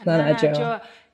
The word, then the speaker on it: Nana Adjoa: That's Nana Adjoa.